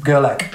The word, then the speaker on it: Gerlach: Pronunciation of his surname, is it Brad Gerlach? – Brad Gerlach, yeah, Gerlach.